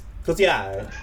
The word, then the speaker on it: Cotillard: How do you say it? – You're from Montreal. – as of this week, past 2 weeks, yeah. Yes. It's allow me. It's Cotillard.